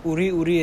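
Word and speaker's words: Uri, Uri (עורי עורי): Uri, Uri is Milera, meaning you read it with the emphasis on the end.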